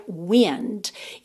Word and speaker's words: wind: Atenção para a pronúncia desse verbo wind, porque a palavra se escreve exatamente igual a wind, vento, W-I-N-D. Só que o vento a gente pronuncia wind. E esse verbo aqui, wind.